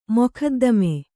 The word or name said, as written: ಮೊಕದ್ದಮೆ